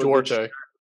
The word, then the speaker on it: Duarte: But and for the Pacers, Josh Duarte, if I'm even pronouncing that correctly, he's also Duarte. – Yeah, there you go.